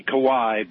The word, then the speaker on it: Kauai: The coffee, your brew, is grown on the island of Kauai. – Whoa, wow. – Some say Kauai, but it's pronounced Kauai.